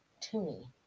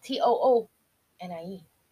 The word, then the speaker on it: toonie: and came out with a coin that is called a toonie, and that's real, and it's spelled T O O N I E. – Yeah like a tune.